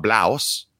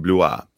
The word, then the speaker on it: Blois: He was born in a place in France called Blois, which I had to look up because it's spelled B-L-O-I-S, not Blaus. It's actually pronounced Blois.